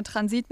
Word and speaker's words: Transit: Du hast auch Transit gesagt, ne? – Ich sage Transit mit der Betonung auf dem I und hoffe, dass es stimmt.